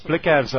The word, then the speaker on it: Blicavs: It's Blitzars? – Blicavs, I think, is the way that I've uh, heard it pronounced, yeah. Because yes. before the game, uh, Nicole Seekamp said Blitzavs.